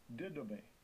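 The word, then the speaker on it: Didomé (δίδωμι): Didomé is defined as to give something to someone, to give of one's own accord, to give one something to his advantage.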